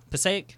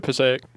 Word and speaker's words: Passaic: Movie actor born in Passaic, New Jersey. Did I say that right? You ever heard of that place? – Mm-hmm. – Passaic? – Been to Passaic.